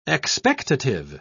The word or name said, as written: expectative